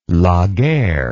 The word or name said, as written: Laguerre